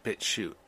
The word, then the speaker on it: BitChute: And BitChute is spelled BitChute, not Shoot. It's BitChute.